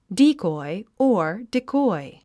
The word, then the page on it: decoy: díːkɔi; ディコォイ